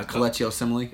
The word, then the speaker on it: Kelechi Osemele: Supposedly reports are going around that maybe Kamishi Osule, is that how you say his name? – Kelechi Osemele. – Yeah, Kelechi Osemele. Yeah, I botched that one.